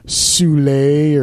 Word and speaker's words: Soule: he can put us in contact with Mr. Soule, or apparently his name is pronounced Soul. Sorry. Was I the only one who thought it was pronounced Soule? I thought it was Soule too, man.